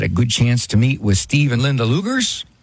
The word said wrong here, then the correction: Leagers: Oh, Leagers. That's the way to pronounce it. Sorry about that. Pronounced Leagers.